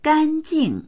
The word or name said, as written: gan1jing4